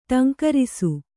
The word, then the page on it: ಠೇಂಕರಿಸು: ṭhēŋkarisu